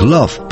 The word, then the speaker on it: love: На этом английском слове «love» любят играть французы, но совершенно в неожиданной области, так как «love» произносится практически через «а»,